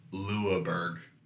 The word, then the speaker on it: Louisbourg: I believe it's pronounced Louisbourg.